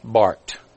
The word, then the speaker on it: Barth: Enter Karl Barth. His name is spelled B-A-R-T-H, but it's pronounced Bart, as in Bart Simpson.